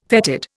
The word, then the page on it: fetid: Indian